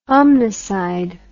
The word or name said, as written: omnicide